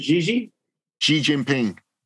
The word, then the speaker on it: Xi Jinping: Xi Jinping. Yeah, the XI is pronounced G, not C, but G.